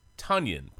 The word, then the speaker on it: Tunyon: And I will say again, it is Tunyon, pronounced like Funyon. Just remember Funyon, and you can get Tunyon right.